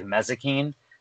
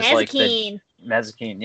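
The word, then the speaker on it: Mazikeen: No, I always say her name wrong, but it's like Mezzakine, who is Mezzakine.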